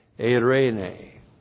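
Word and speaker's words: eirene (εἰρήνη): Eirene. Great, great word in the Greek, and it has an equivalent in the Hebrew, shalom. E-I-R, long E, N, long E, eirene.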